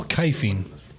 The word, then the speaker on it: kife: You spell kifing? – Try K-I-F-E. – K-I-F-E. – That's what I would think, yeah. – Definition. – Like knife, but the N is silent.